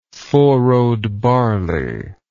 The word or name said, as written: four-rowed barley